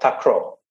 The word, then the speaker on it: takraw: It's called takraw.